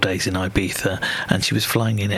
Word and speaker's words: Ibiza: She's had, I think, either three, might have even been 4 days over in Ibiza, which I'm going to pronounce it properly as Ibiza, unlike the Venga Boys coming up.